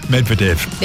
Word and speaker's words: Medvedev: And Andrei Medvedev. Is that how you say his name? – Close enough. – Good. Well, the Ukrainian tennis player is 47. – Medvedev.